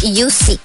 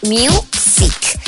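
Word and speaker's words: Music (µ-Ziq): Entonces, tenemos una canción que se llama Hello y que es por parte de Mike Paradinas, que se hace llamar Music o Music. Se escribe raro, pero sí es como Music.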